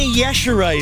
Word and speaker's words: Yashere: Gina Yashere is the correct pronunciation of our guest, Dave.